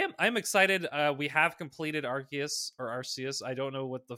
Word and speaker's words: Arceus: Because it goes with Deus. – That makes a lot. – Latin for God. So. – Okay. So I will continue using Arceus, but Arceus is the proper pronunciation.